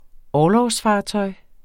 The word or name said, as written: orlogsfartøj